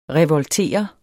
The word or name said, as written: revoltere